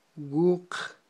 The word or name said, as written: wuq